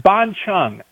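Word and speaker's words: bánh chưng: It's B-A-N-H-C-H-U-N-G. I think I remembered that right. – Uh-huh. – And I think it's pronounced ban chung.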